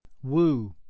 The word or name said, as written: woo